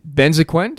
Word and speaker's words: Benzaquen: Everyone, I'm here today with David Benzaquen. Do I pronounce it correctly? – That's perfect.